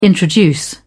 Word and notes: introduce: British English

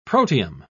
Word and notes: protium: British English

